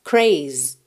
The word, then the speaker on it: craze: Então, ele é dance, termina no som s, craze, termina no Z, craze, craze.